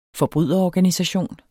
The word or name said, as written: forbryderorganisation